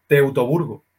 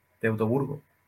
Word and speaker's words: Teutoburgo: Teutoburgo. Teutoburgo.